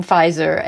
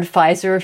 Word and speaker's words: Pfizer: And he has these linguistic ideas about why the word Pfizer feels luxurious because it has a silent letter in it, because it's a proper name which people associate with designers.